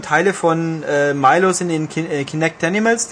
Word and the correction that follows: Kinectimals: drin. Also was oder? Kinectimals? – Kinectimals. – Äh, okay, Kinectimals. Jetzt habe ich es richtig gesagt, Kinectimals. Oder? Äh, jetzt war es richtig, ja.